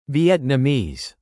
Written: Vietnamese

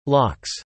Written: locks

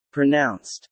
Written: pronounced

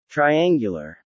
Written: triangular

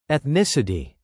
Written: ethnicity

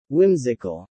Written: whimsical

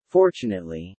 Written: fortunately

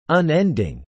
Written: unending

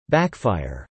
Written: backfire

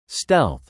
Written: stealth

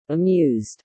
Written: amused

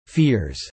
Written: fears

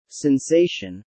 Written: sensation

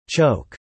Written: choke

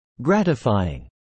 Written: gratifying

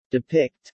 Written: depict

